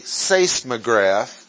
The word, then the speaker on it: seismograph: S-E-I-S-M-O-S. Seismos is the Greek word that means shaking. We have it in English with a seismograph, only we don't pronounce the E-I, we pronounce it as seis, seismograph.